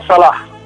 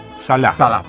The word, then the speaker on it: Salah: ¿Es Sala o Salah? ¿Cómo es que se pronuncia? – Se pronuncia Salah. – Salah, perfecto.